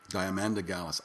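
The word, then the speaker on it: Diamanda Galás: I was going to mention this first, but I'll just jump to it, and then we'll jump off to Diamond of Gallus. Diamanda Gallus, I think is how it's pronounced.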